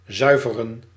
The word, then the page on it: zuiveren: /ˈzʌj.və.rəⁿ/